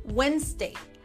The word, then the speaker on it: Wednesday: Por ejemplo, la palabra Wednesday, que es miércoles, tiene como dos o tres letras silenciosas. Tú escuchas Wednesday, pero se escribe Wednesday. Tiene como tres letras, ¿no?